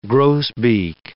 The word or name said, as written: grosbeak